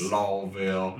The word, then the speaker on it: Louisville: I know there's a lot of different pronunciations of it. Some people say Louisville. – Yes. – Louisville. But God's country. – Louisville is how I always heard it.